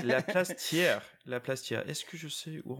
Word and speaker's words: Thiers: It was called like La Place Thiers, Thiers. It's pronounced Thiers.